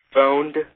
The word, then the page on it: phoned: American English